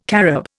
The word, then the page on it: carob: Indian